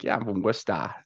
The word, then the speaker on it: Worcester: So yeah, my stepdad's from Worcester, just right out. Yeah, Worcester, Massachusetts. So it's funny. – You said it right though.